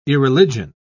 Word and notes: irreligion: British English

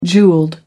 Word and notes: jewelled: American English

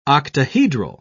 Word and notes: octahedral: British English

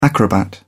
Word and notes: acrobat: British English

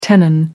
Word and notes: tenon: American English

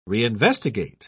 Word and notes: reinvestigate: British English

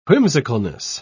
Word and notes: whimsicalness: British English

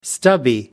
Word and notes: stubby: American English

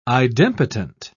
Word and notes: idempotent: British English

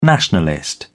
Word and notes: nationalist: British English

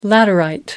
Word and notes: laterite: American English

